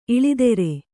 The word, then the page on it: ಇಳೆದೇರ: iḷedēra